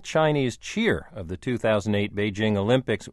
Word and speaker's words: Beijing: Since we've adopted the word Beijing, I think a lot of people think that because it's a Chinese word, it should be pronounced in a foreign-sounding way. So I think a lot of people simply adopt a French-sounding jeu sound rather than the very simple English jing.